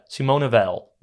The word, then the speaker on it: Simone Weil: Welke letter spreek je wel of niet uit? – Simone Weil? – Ik denk dat dat is. – Ja, Simone Weil.